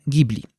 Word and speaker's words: Ghibli: Otóż słowo Ghibli jest słowem z języka włoskiego. I jak najbardziej po włosku Ghibli czyta się Ghibli.